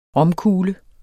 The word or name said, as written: ROMKUGLER